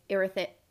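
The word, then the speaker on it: Irithys: And her kind of doing a little test to see if Erythus, I'm sorry for mispronouncing that. We didn't listen to the audible, but like Erythus. – That's how I say it.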